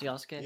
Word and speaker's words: Yasuke: Looks like Yasuke, pronounced Yasuke.